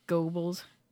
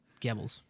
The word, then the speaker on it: Goebbels: You know, Biden called Holly like the Joseph Goebbels. – Goebbels. – Goebbels. Thank you for the pronunciation.